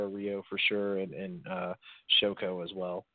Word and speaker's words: Shoko: I'm pretty sure I did, but that's how you pronounce it, everyone. Shoko.